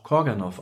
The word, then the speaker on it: Karganoff: Genari Karganoff, manchmal auch Korganoff ausgesprochen, war ein russischer Komponist armenischer Abstammung, der leider sehr jung im Alter von 32 Jahren starb.